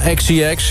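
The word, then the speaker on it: Charli XCX: Nummer 2, Charlie XCX. Zeg ik dat goed? – Ja, XCX.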